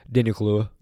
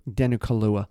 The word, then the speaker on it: Kaluuya: Daniel Kaluuya. – Daniel Kaluuya.